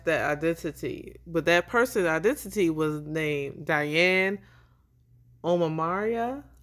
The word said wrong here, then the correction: O'Meara: It's Omera. Like O M E A R A.